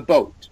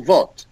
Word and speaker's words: boat: Before the T, there's like a hidden W. So watch me. Boat. – Boat. Okay. Boat. – Look. I will, because you normally would say, I will vote.